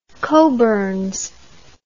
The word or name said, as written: cockburns